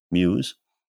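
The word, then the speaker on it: MEWU: These often include seats of ease, euphemistically called mobile expandable well-being units, or MEWUs, pronounced Muse.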